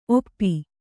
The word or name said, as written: ಓಪು